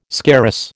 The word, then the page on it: scarus: American English